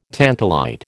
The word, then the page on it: tantalite: American English